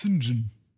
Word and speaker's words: St. John: Ich ich war überrascht, jetzt irgendwie im, im, im geschriebenen Wort zu lesen, quasi Saint John Smythe, weil äh, ausgesprochen wird immer als Sinjin.